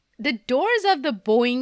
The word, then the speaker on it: Boeing: It's Boeing. Boeing. Okay. Like a bow and arrow. Okay. – Yeah. But it's spelled more like boing.